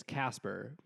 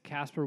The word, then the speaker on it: Kasper: Like, because my last name is Casper, but it's spelled with a K. So it's always Casper with a K or Casper, K-A-S-P-E-R. Mm-hmm.